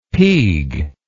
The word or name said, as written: peag